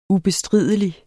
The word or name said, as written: ubestridelig